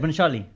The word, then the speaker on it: Bhansali: You just said Bhansali. It's hard to say. – But that's how it's said. Bhansali.